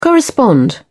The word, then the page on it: correspond: British English